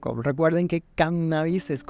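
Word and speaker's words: cánnabis: Sin embargo, durante las últimas décadas se ha introducido en nuestra lengua el término cannabis, con el acento en la primera A, tomado a través del inglés de la denominación científica latina y que ha ido desplazando a las anteriores palabras.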